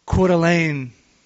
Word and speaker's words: Coeur d'Alene: If you live in Coeur d'Alene, I think I'm finally pronouncing that right now. Coeur d'Alene, Idaho.